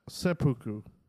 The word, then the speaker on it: seppuku: Second one was seppuku. There's no N in it. Seppuku.